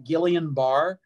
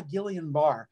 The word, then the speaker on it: Guillain-Barré: And a lot of people pronounce it incorrectly. They pronounce it Guillain-Barré. So if you're listening to this and you go, oh yeah, Guillain-Barré, it's actually Guillain-Barré, named after two Frenchmen.